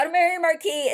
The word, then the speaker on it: marquess: Is it marquis or marquis? – In French, it's marquis. But in English, it's marquis. M-A-R-Q-U-E-S-S is pronounced marquis.